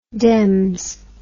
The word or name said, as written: dems